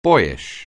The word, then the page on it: boyish: anglais